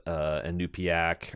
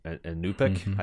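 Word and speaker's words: Iñupiaq: the Inupiaq or Inupiaq, I think is how it seems like the natives are pronouncing it.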